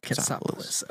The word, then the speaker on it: Katsopolis: K-A-T-S-O-P-O-L-I-S. – Katsopoulos.